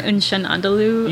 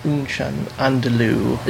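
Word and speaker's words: Un Chien Andalou: Unchan Andalou? – Yes, Unchan Andalou.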